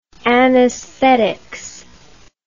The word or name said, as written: anaesthetics